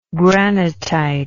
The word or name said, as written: granitite